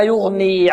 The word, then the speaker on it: يغني: طيب وما يغني عنه ماله إذا تردى. وما يغني، الغين مفخمة والياء مرقة، وما يغني عنه، عنه النون بعدها حكمها ماذا؟